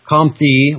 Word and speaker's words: Comte: In his system, and that'd be his system, Comte, or Comte, just bury the last syllable there, the last part of that word, Comte. It's like count.